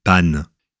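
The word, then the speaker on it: paonne: La femelle de cet oiseau suit la même règle, puisque la paone se dit panne.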